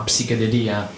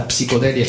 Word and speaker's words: psichedelia: E un'altra cosa gli esperti si saranno resi conto, che noi italiani siamo preda di spagnolismi, questo itagnolo o spanitaliano, quindi non si dice psichedelia ma psichedelia, la psicodelia è solo in spagnolo.